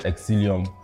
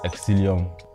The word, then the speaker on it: XCELIUM: A lot of people don't know how to pronounce it because of the X and the C, but it's pronounced Exilium. And Instagram and TikTok is xce.lium. And then on YouTube, it's just Exilium.